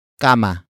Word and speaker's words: kama: That object is kama. Kama. Kama. Kama is spelled as K-A-M-A.